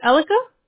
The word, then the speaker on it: Elika: Is it correctly said Elika? – Yes, you're right. – Oh, perfect. And that's E-L-I-K-A, correct? – Yes.